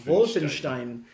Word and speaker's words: Wolfenstein: That's the correct way to pronounce it, by the way. – Wolfenstein.